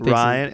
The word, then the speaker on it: Ryan: I say Everyone Ryan, and I even spell out, you know? I'm like, R-Y-A-N.